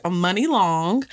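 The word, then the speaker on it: Muni Long: Okay, so this my bop of the week is by, her name is pronounced Money Long. It's spelled M-U-N-I Long.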